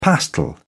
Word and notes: pastel: British English

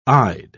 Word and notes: ide: British English

